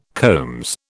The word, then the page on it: combs: British English